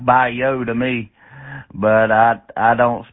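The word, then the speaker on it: Bayeux: Bye-yo. How copy? – I copy fine. That would be pronounced in French, bye-yo.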